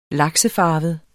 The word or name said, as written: laksefarvet